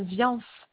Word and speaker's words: Viance: Je suis madame Viance.